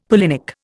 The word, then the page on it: pollinic: Indian